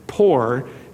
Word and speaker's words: pur: It's very interesting to discover that the word poor, literally, it's pronounced as if it were spelled P-O-O-R, even though it's spelled P-U-R.